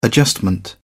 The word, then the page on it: adjustment: British English